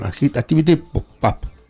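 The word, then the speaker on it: ActivityPub: Activity Hub, perdón, Activity PAP. Se pronuncia así, ¿verdad? Sí.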